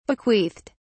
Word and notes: bequeathed: British English

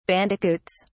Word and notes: bandicoots: British English

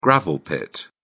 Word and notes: gravel pit: British English

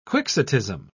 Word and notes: quixotism: British English

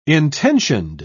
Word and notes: intentioned: British English

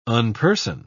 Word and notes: unperson: British English

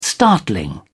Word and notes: startling: British English

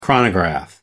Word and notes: chronograph: American English

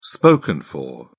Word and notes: spoken for: British English

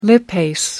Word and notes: lipase: American English

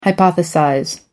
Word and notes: hypothesize: American English

